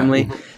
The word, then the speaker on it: Brimley: Wilford Brimley. Am I saying it right? – Diabetes? – Yes. – Ah, okay, cool. – Yeah. Brimley.